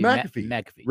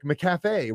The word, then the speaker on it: McAfee: Is it McCafe or McAfee? – I always thought it to be McAfee, right? – John McAfee.